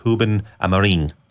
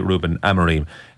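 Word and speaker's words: Rúben Amorim: it's Ruben Amorim. – Yeah. – But it's totally acceptable to say Ruben Amorim. Anyway, whichever way you want to pronounce it, the first week of his tenure in terms of games, it's been interesting and decent.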